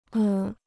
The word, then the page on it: 恶: è